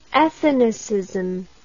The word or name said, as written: ethnicism